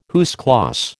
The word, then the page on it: à huis clos: American English